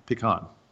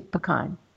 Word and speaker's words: pecan: How do you pronounce P-E-C-A-N? – Pecan. – Me too, pecan. But some people say pecan.